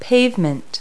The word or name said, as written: pavement